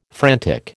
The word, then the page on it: frantic: American English